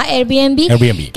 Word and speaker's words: Airbnb: Airbnb. Ajá, Airbnb, Que es para vacacionar y está muy de moda hoy en día.